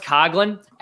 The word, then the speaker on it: Coughlin: I should mention too, it's pronounced Coughlin. Everyone says Coughlin.